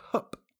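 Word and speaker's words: Höfn: And then, of course, I got stuck in a town called Hup, spelled H-O-F, with a little accent on the O.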